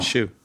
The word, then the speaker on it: Hsu: H-S-U. There you go. – Shu. – Is it Shu? – That's how I pronounced it. – Okay. I thought it was like Sue.